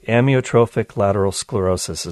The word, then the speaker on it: amyotrophic lateral sclerosis: Yeah, and ALS, and let me know if I'm pronouncing this right, amyotrophic lateral sclerosis is the technical name for the disease. – You got it.